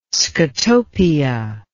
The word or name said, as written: scotopia